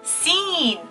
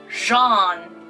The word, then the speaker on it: Jean: Number five. Scene. I love your new jacket. That looks great on you. Uh, uh, nope. The right one is Jean, Jean, Jean.